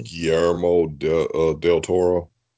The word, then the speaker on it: Guillermo del Toro: Del Toro, Guillermo Toro, whatever you say his name. – Guillermo del Toro.